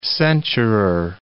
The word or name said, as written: censurer